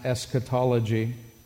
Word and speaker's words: eschatology: The term is actually from the Greek, and in English it's pronounced eschatology. It's made up of two primary words, eschatos and ology.